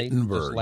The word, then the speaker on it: Wittenberg: Wittenberg, yes.